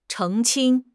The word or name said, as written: 澄清